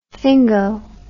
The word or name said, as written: fingo